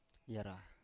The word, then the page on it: yara: Biak